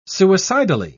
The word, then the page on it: suicidally: British English